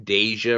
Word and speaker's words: Deja: Am I pronouncing Deja right? – Yep, I feel like you've said it before.